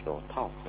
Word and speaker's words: 手套: 然后手套这个发音呢是四声的。 – 手套。